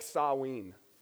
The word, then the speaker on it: Samhain: Samhain? Somebody like, first name Sam, last name Hain? It's actually pronounced more like Saween.